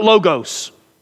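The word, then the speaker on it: Logos (λόγος): Now I don't know how to pronounce every Greek word right, but I know how to pronounce this one right. Logos. That's right. Not logos. I know we're in the South, but it's not logos. It's logos. And this word literally means this.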